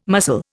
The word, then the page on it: mussel: Indian